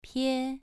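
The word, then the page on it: pie: tone 2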